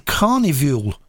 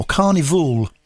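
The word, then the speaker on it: Karnivool: It's from a brand new album from quite a heavy band, and they're called Carnivule, or Carnivule. Spelt with a K, K-A-R-N-I-V-O-O-L.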